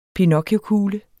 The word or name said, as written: PINOCCHIOKUGLE